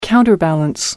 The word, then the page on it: counterbalance: American English